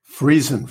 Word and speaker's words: Friesen: Is it Friesen? – Friesen. Very good. Perfect.